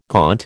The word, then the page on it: gaunt: American English